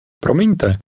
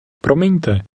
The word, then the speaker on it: Promiňte: Promiňte. – Listen to the speaker and repeat after him trying to make your pronunciation sound like his. – Promiňte. Promiňte.